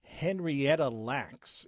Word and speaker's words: Henrietta Lacks: The Immortal Life of Henrietta Lack was one which was brought up by a couple of people. Excuse me. Henrietta Lacks is the correct pronunciation there.